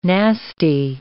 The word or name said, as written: nasty